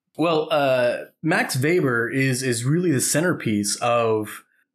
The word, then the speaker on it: Max Weber: and crucially, his name is pronounced Max Weber. – That's right. Mr. Weber. – But it's spelled Weber.